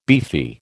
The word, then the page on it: beefy: American English